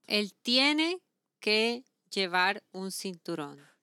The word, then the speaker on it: cinturón: Cinturón. – Cinturón. – Cinturón. – How do you spell that? – C, as in Carlos. – Yeah. – I-N-T, as in Thomas. U-R-O-N, as in Nancy. – Cinturón.